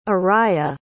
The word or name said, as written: araya